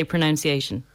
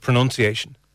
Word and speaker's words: pronunciation: Okay, the final common mistake and with the word pronunciation itself, many people get confused with the sound of the verb and form pronounce, oh, pronunciation, and say pronunciation. – Instead of pronunciation.